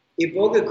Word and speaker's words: iboga: It's uh. pronounced both, both ways. – Okay. If I were in Gabon, how would I pronounce it? – Uh, Iboga. Iboga.